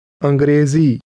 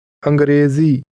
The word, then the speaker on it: Angrezi: Angrezi. Z. Grezi. Ang. Angrezi.